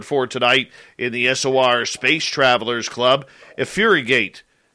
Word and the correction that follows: Effigurate: Oh, I butchered that. Effigurate. There we go.